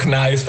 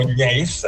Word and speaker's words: gneiss: Sì, no, io lo pronuncio gneiss perché l'80% della mia clientela è di lingua tedesca.